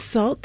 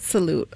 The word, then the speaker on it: Sault: It's salt, that's how you say it? – S-A-U-L-T. – Yeah, I thought it was salute. Okay.